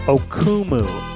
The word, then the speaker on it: Okumu: Anyway, that's Dave Okuwu. Am I saying his name right? Oh my goodness. Dave Okumu. O-K-U-M-U.